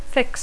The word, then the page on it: fix: English